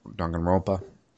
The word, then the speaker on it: Danganronpa: And then you mentioned Danganronpa. Is it Danganronpa? Is that how you pronounce it? – I'm not an authority in pronunciations, but I've always pronounced it Danganronpa. – Danganronpa.